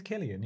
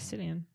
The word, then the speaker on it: Cillian: Is it Killian? – I think it's Killian, yeah. – Yeah, I think it's Killian. – Oh, I thought it was Cillian. Yeah. I was saying it wrong this whole time.